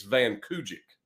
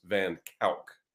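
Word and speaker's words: van Kuijk: And so it looks like when you look at his last name, it looks like it's Van Kujik is how I would pronounce it. But it's actually pronounced in the Netherlands, in Holland, it is pronounced Van Kalk.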